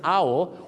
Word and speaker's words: owl: กฮูกครับนกฮูกต้องออกเสียงว่าอ้าวไม่ใช่โอนะครับตอนนี้อุทานอ้าวเหรอออกเสียงว่าโอมาตลอดชีวิต wise as an owl